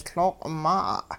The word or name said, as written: L'ooq maa'a'